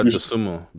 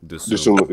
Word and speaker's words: Dosunmu: How do you say his last name, by the way? I always like fuck it up. – Ayo Dosunmu. – Dosunmu, yeah. – Dosunmu. Okay.